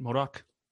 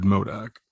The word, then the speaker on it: MODOK: Did I say that right? MODOK? – You did, MODOK.